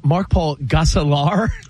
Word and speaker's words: Mark Paul Gosselaar: It's Mark Paul Gosselaar.